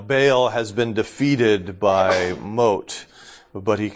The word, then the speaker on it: Mot: It talks about death, and death was often personified as the god Mot, M-O-T. Even though it's pronounced Mot.